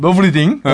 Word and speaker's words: 노브레싱: 제가 노브레싱이라고 발음했던. – 노브리딩. – 노브리딩. – 아니 뭐 그 노브레싱이라고 그 한글로 표기되어 있어요. 네. 뭐 우리는 그 한글 표기를 존중해야지.